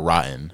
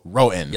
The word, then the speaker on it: Roten: So I feel bad for asking you this, but is your last name pronounced Roten or Rotten? – Roten. – Roten. – Yeah, like Roten. – Okay, my bad. – There's no W.